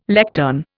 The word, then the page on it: lectern: Indian